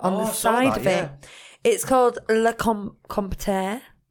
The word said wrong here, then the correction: Comptoir: Can someone just confirm I'm saying that right? Top bullet point. Le Compteur. – Comptoir.